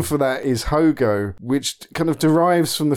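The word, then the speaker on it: haut-goût: So Hogo is literally H-O-G-O, whereas the French term is H-A-U-T-G-O-U-T. – Oh, okay. – So I think they pronounce the same, which translated means high taste, and high taste refers to a kind of gaminess, just on the turn of being rotten.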